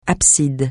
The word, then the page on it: abside: francais